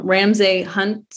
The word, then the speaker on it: Ramsay Hunt: And now her husband has something I never heard of before, but it's called, tell me if I'm pronouncing this wrong, Ramsay Hunt syndrome. – That sounds pretty good.